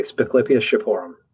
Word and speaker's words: Spiclypeus shipporum: So, jumping right in, you named one of my favorite sounding dinosaurs ever just about a year ago, which I think is pronounced Spiclypeus shipporum. Is that right? – Yeah, exactly. Spiclypeus shipporum. That's what that's how I say it anyways.